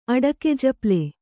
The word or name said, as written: ಅಡಕೆಜಪ್ಲೆ